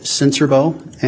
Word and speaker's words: Sincerbeaux: My name is David Sincerbo, and my last name is spelled S-I-N-C-E-R-B-E-A-U-X.